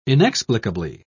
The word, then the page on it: inexplicably: British English